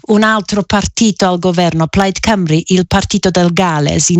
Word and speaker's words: Plaid Cymru: Certo, e come correttamente ricordavi, correttamente pronunciavi, la vittoria di Plaid Cymru,